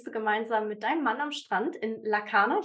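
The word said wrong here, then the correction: Larnaka: Ich hoffe, das ist richtig ausgesprochen. – Lanaka. – Lanaka, okay, mhm.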